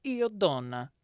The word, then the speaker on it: Io Donna: In a recent interview in an Italian magazine called Io Donna, you have to say it like that.